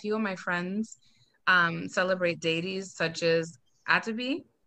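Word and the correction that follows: Atabey: Atabi? Is that how you pronounce it? – Atabe. – Yes, sorry.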